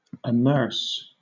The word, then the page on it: amerce: British English